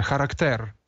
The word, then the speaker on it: характер: Але тут йдеться про щось інше, характер. Ось наголос на останньому, це грецьке слово.